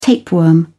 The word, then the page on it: tapeworm: British English